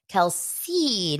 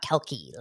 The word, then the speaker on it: Kelce: Travis Kelsey, not Kelke, like I've heard a couple people pronounce.